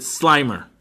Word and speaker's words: Slimer: Ghostbusters, Slimmer Twinkies. – Slimmer. – Slimmer. – Oh, it's spelled Slimmer. – So I'm supposed to pronounce it Slimer. – Yes. Even though you spelled it Slimmer.